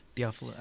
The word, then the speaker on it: Theophilus: Well, one thing off the top is that with this, how do you say it? Theophilus? – Theophilus, yeah.